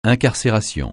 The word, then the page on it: incarcération: francais